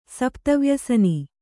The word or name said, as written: ಸಪ್ತವ್ಯಸನ